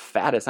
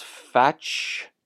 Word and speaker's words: fatuus: It's not fatuus. I need to learn Latin. Wow, I've been pronouncing it wrong for like 7 years. Ignis is correct, but it looks like it's fatuous. Ignis fatuous. Like flatulence? I don't know.